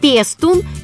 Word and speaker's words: пестун: Как правильно спрашивают нас, пестун или пестун? И что значит это слово? Пестун. Именно так надо произносить это слово сегодня. Ударение «пестун» устарелое. С таким ударением «так» произносили это слово поэты пушкинской поры.